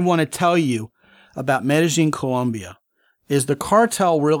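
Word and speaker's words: Medellín: Oh, yeah, because the double L is a J instead of a Y, and they really kind of slur their syllables together. So to them, the name of the town is, as I'm saying it, Medellin, actually Medellin.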